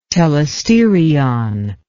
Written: telesterion

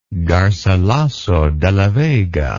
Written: garcilaso de la vega

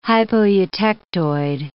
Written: hypoeutectoid

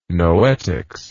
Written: noetics

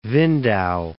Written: Windau

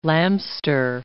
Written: lamster

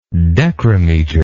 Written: decremeter

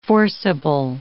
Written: forcible

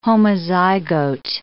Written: homozygote

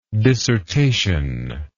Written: dissertationist

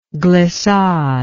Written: glissader

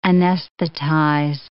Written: anesthetize

